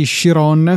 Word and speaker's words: Chiron: Bucati Chiron, questa credo che sia la pronuncia che ci siamo eh, assegnati. – Sì, sì, ci siamo perlomeno ass- accordati unanimamente su questa.